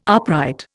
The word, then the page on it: upright: Indian